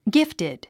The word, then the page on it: gifted: ɡíftid; ギフテッド